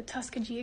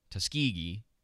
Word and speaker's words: Tuskegee: Again, if you're gonna sound like you know what you're talking about, you should at least be able to correctly pronounce the name of the experiment. Tuskegee, not Tuskegee.